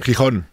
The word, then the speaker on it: Gijón: Yeah, in Gijón, is that how you say it? – Gijón. – Gijón, yeah.